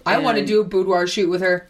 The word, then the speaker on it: boudoir: She's done so much stuff, but right now her focus is boudoir. Is that how you say it? – Yeah, boudoir.